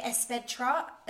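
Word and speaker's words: Es Vedrà: And it was I was like near Esvedra. – Yeah. – Is that how you say it? – Yeah.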